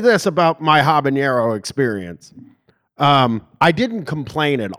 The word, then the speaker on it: habanero: And it gave me it unlocked the power to pronounce it correctly. – Habanero. Yes.